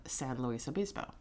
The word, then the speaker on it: San Luis Obispo: I have only heard of San Luis Obispo.